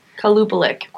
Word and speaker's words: Qalupalik: We pronounced it wrong, guys, sorry. – We were pronouncing it wrong. We do apologize. – Qalupalik.